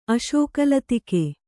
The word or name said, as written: ಅಶೋಕಲತಿಕೆ